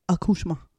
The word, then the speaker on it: accouchement: So, accouchement. – Accouchement. And then the accouchement, it's M-A, but it's spelled M-E-N-T, but it's right. Accouchement. – Because it's French. – Okay. – In French. M-E-N-T spells ma.